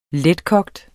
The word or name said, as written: letkogt